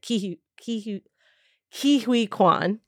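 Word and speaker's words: Ke Huy Quan: And then you get I think it's Ki-hui. That's how you say it. Ki-hui Kwan. – Ki-hui Kwan.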